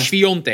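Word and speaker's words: Świątek: Maar Sviontek. Er staat Swiatek, maar je...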